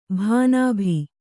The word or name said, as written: ಭಾನಾಭಿ